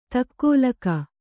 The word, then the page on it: ದೊಗ್ಗಾಲಿಕ್ಕು: Kannada